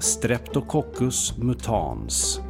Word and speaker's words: Streptococcus mutans: Ja, då har jag nu varit på folktandvården och fått reda på att det uttalas Streptococcus mutans.